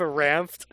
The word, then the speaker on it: Ranft: How do you spell that Ranft? – R-A-N-F-T. – Oh, okay. Just how it sounds. Okay. I thought maybe there was a D in there.